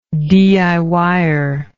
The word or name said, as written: diyer